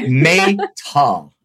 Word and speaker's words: Maytal: The name is Maytal, like the month of May and tall. Maytal.